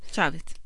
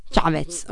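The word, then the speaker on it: Chávez: Ecco, Chavez, giusto si pronuncia così? Chavez? Chavez. Chavez, ok.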